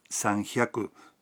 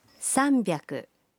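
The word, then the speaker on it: sanbyaku (さんびゃく): 300 no se pronuncia sanhyaku, sino sanbyaku.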